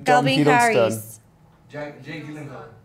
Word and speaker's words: Gyllenhaal: Gyllenhaal. – Gyllenhaal.